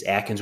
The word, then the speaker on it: Atkins: For this act, Staff Sergeant Travis Atkins' family would be, well, so the right way to say it is Atkins would be awarded posthumously the Medal of Honor.